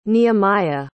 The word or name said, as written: nehemiah